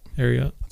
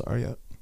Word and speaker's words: Ariat: That's how you pronounce it, Ariat? – Ariat. – That's Ariat.